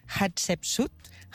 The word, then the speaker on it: Hatshepsut: no sé si ho diré bé, Hatshepsut. – Molt bé, però ho has pronunciat molt bé, eh?